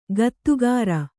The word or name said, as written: ಗತ್ತುಗಾರ